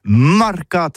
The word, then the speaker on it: marcat: c'est prononcé sur les marcat, avec un A, marcat. En gascogne toulousaine, bah, du côté, par exemple, de l'île des Baches, c'est l'île Jourdain, pour parler du marché, vous prononcez les marcat.